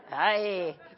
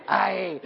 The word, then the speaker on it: Ai: How do you say that? Ai? Ai, it's a pirate city. Ai.